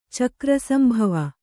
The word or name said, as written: ಚಕ್ರಸಂಭವ